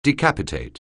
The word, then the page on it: decapitate: anglais